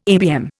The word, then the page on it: ABM: Indian